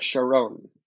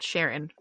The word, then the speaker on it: Charon: I think it's like Charon. – Charon, okay. Because I was like, it's not Sharon.